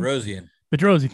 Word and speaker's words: Bedrosian: Bedrosian, I think is how you say his name. – Bedrosian, thank you.